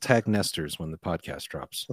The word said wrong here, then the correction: Nectar's: Nectars, not Nestors.